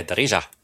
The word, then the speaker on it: Darija: Did I pronounce it right? – Yeah, Darija.